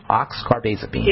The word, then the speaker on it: oxcarbazepine: They just went right into the oxcarbazepine. Oxcarbazepine. – Yeah, that's how you say it.